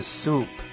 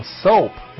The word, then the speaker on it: soup: 对，汤这个单词的发音是 soup。 – 嗯哼。 – 啊，大家注意啊，你要把那个读成 soap，那是什么？ – 肥皂。 – 肥皂不是肥皂啊，应该是 more soup。 – OK, soup 不是 soap。